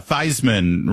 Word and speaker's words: Theismann: He should do what Joe Theismann did when he said, oh, yeah, Theismann rhymes with Heisman, and then he won the Heisman Trophy that year, even though he never pronounced his name that way.